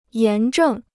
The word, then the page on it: 炎症: yán zhèng